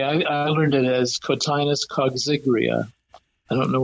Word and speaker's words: coggygria: The main species grown is called Cotinus coxigria, which is an odd pronunciation, but especially it's spelled C-O-G-G-Y-G-R-I-A.